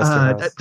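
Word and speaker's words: Eszterhas: And obviously there are clear times when Esteraz, is that how you say it? – Yeah, Joe Esteraz.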